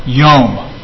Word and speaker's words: yom: I just want to tell you about this Hebrew word, yom. Spell it in English, Y-O-M. It's not some Middle Eastern meditation. Yom. No. It's the Hebrew word for day, yom. Y-O-M.